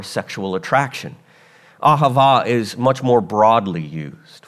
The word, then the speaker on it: ahava: And the first one is the Hebrew word ahava.